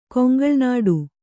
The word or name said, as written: ಕೊಂಗಲ್ನಾಡು